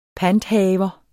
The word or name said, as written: PANTHAVER